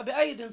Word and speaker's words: بأيد: بأيد بأيد، أدي أدي ياء وياء، هي ما تنطق، هتتنطق بأيد صح، لكن مش هيبقى إيه، لكن كتابتها بيائين، يبقى قال لك ده يبقى دي مش جمع يد.